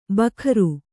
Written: ಭಕಾರ

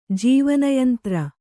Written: ಜೀವನಯಂತ್ರ

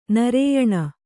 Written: ನಾರಾಯಣ

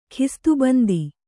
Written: ಖಿಸ್ತುಬಂದಿ

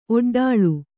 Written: ಒಡ್ಡಳಿ

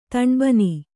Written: ತಣ್ಬನಿ

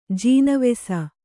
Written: ಜೀನವೆಸ

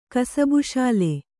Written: ಕಸಬುಶಾಲೆ

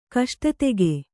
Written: ಕಷ್ಟತೆಗೆ

